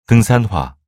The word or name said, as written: 등산화